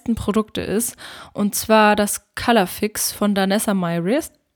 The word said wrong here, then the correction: Myricks: Myrix, so spricht man das aus.